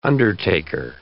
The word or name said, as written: undertaker